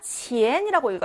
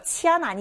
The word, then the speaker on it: 钱: 치엔이라고 읽어. 치안 아니죠. 예외 발음이었습니다. 치엔, 치엔, 돈이고요.